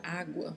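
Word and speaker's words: agua: Você pode pronunciar a letra G quase como se fosse dizer a palavra sem o som desta letra. Água.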